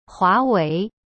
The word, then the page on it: 华为: hua wei